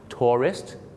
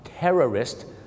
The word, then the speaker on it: tourist: สองคำนี้มีเส้นบางๆอยู่นะครับในการออกเสียงคือพยางค์สองพยางค์ tourist นักท่องเที่ยวกับ terrorist สามพยางค์ครับ